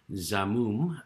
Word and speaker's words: Xamoom: There's a company there called Zamoom. It's spelled X-A-M-O-O-M.